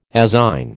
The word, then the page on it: azine: American English